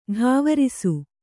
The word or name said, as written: ಢಾವರಿಸು